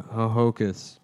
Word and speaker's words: Ho-Ho-Kus: Yeah, but it's spelled H-O-H-O-K-U-S. – That's really dumb. – Yeah, Ho-Ho-Koos.